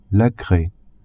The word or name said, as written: craie